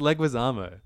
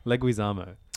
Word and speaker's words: Leguizamo: I said John Leguizamo. – Yeah. John Leguizamo. Is it Zamo? – John Leguizamo. No, it's John It's Bob Mamma Mia Hoskins and John Leguizamo. – No, it's Leguizamo. Leguizamo.